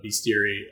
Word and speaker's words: bestiary: Typically, you don't talk about a bestiary of real animals very much. But anyway, but beast is not spelled like the word beast. It's spelled like best.